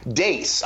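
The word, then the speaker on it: Deace: And I now know that your name is pronounced Dace. I was calling you Steve Deese for the longest time.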